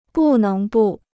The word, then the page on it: 不能不: bù néng bù